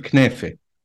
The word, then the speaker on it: knafe: And also, I'm curious about the knafe, and I know that you pronounce it also differently in Lebanon, of the Lebanese version. – Yes. So, yeah, we call it knafe.